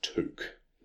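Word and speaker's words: Touk: And a lot of commentators call him Took Miller, but it is Took.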